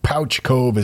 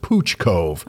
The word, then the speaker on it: Pouch Cove: Anyway, I feel the writer set you up a bit because Pouch Cove is actually pronounced Pooch Cove. – Oh my goodness. – I called it Pouch Cove too, but my sister-in-law lived there and says it's Pooch Cove.